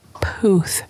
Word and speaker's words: Puth: Did I say that wrong? – Yeah, it's okay. – How is Charlie. – It? Charlie. – Charlie. Puth. – Pluth. – Noel. Puth. – Puth. – There you go.